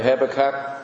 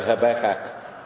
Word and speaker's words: Habakkuk: Some say Habakkuk, I say Habakkuk. So that must be the right pronunciation.